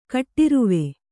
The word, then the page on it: ಕಟ್ಟೇರ್ವqÉ: kaṭṭērvaḍe